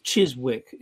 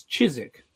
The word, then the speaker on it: Chiswick: The other day he said Chiswick. It's not Chiswick. It's Chiswick.